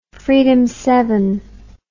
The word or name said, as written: freedom ride